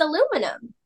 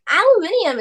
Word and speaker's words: aluminium: it's not, I mean, see, everyone here says aluminum, and aluminium is so much cooler.